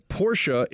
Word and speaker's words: Porsche: Porsche is the correct pronunciation, not Porsche.